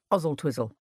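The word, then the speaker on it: Oswaldtwistle: Oswald Twistle, pronounced Ozzle Twizzle.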